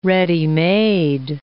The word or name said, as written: ready-made